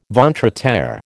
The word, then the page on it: ventre à terre: American English